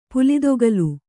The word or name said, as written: ಪುಲಿದೊಗಲು